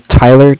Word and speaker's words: Tyler: My name is Tyler, T-Y-L-E-R, and I'm with Bank of Cardiff. That is spelled C-A-R-D-I-F-F, as in Frank Frank.